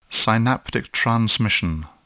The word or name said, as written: synaptic transmission